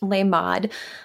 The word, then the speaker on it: Lamed: I am pretty sure it's pronounced Lamed,